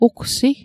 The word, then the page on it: ugsi: Mi'gmaq